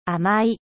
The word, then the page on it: sucré: French